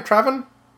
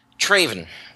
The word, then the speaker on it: Trayvon: Then we're going to talk about, how do you pronounce his first name, Trevin? – Traven. Traven Martin. I wrote down the spelling. T-R-A-Y-V-O-N.